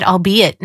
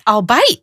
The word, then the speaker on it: albeit: And I looked at the spot and I was like, no, that's albite. Oh no, have I been saying it wrong in my head this whole time? I never used it out loud, right. – but I've been using it wrong. I've been saying it and pronouncing it wrong this entire time.